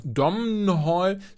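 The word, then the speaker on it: Domhnall: Dom Hall. Spricht man das eigentlich, ist das N eigentlich silent? Spricht man ihn einfach Dom Hall aus oder Dom Hall? Oh Gott. Ich würde das N nicht aussprechen.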